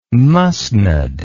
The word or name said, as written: musnud